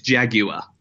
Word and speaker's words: Jaguar: It's got you kind of force an extra syllable out of it. It's Jaguar.